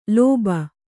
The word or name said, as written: ಲೋಭ